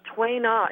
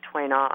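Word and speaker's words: Tuina: So Twaina, it's spelled T-U-I space N-A. I actually had a sign outside of my office that said Twaina on a blackboard, and somebody erased the I and thought it was tuna massage.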